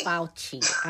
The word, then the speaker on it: Fauci: Dr. Ferrucci? That's how you pronounce his name? – Dr. Fauci. – I done changed the man's whole name.